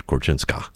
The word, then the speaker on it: Korczynska: And she chose to do that at times because she sort of pronounced it Korczynska, even though it's S-K-I.